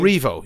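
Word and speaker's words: Rievaulx: Revo Abbey. – Oh, Revo, yeah, in Yorkshire.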